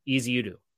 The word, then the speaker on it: Ezeudu: You tell me how to say it. – Ezeudu.